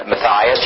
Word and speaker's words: Matthias: I told you about Matthias. That's how they pronounce it. Matthias, you could pronounce it that way.